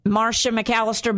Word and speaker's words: Marcia McAllister: Go to YouTube and just put my name in it, Marcia McAllister, but it's spelled a little weird, folks.